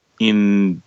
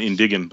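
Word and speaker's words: indign: How do I pronounce it here? Indin. Oh, okay. – Indin. Oh, okay. Indin. – Indin. All right. I would have went Indigan, but all right.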